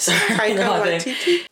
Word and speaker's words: Taika Waititi: How do you pronounce that name? Takiya Waititi? – Taika? – Oh, I'm guessing. – Waititi? – Why, or what? – Taika Waititi? – Takiya Waititi? – There we go.